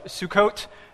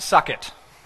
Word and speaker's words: Sukkot: Today, we're going to be worshiping uh, God through the festival of Sukkot. Everybody say Sukkot. Kids, it's not pronounced suck it.